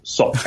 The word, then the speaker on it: Sok: But the way in which this would be pronounced in Khmer, now that I've done this boring thing, is Sok.